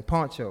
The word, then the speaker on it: Pancho: I'm a shoot at pronouncing his name, Pancho.